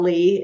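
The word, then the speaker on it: Leigh: It's L-E-I-G-H is how you spell Lee in my case.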